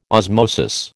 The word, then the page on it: osmosis: American English